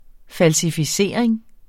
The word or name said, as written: falsificering